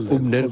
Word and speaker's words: Hübner: No está el poeta, es Hübner. Yo digo Hübner, como se escribe, Hübner, ¿no? Hübner.